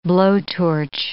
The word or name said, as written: blowtorch